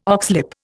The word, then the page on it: oxlip: Indian